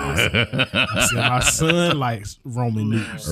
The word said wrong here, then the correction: ramen: Ramen? – Noodles. I don't know how to pronounce it. – And Roman is R-O-M-A-N. – No, it's R-A-M-E-N, fool.